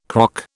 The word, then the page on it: croc: British English